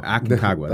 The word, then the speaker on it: Aconcagua: Is it Aconcagua, right? – Aconcagua.